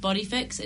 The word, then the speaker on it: BodyFX: I think it's pronounced Body Fix. It's BODYFX.